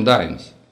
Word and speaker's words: Даймс: Он уже вознамерился влезть в кабину, но почти тут же остановился и, вновь повернувшись ко мне, сказал: «Меня зовут Сэм Даймс. Произносится так же, как название десятицентовика из прошлых времен, хотя и пишется по-другому».